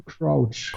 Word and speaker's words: Crouch: Crouch? – Colin Crouch. Crouch, ich hätte ihn auch Krauch genannt, aber okay, Crouch. – So ein bisschen wie Couch, nur mit einem R, Crouch.